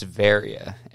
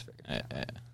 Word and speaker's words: Varia: I'd say Vera. – It's Varia. Everyone's butchered it since I was a kid. – Like, is that Italian? – Yeah, it's Varia. Yeah. I've gotten Varia, Vera. Yeah, you already hit it.